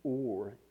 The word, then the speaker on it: Urim: We pronounce it Urim and the Thummim, but it's actually pronounced, hold on just a second. They pronounce the or and the tum. That's how it's actually pronounced in the Hebrew.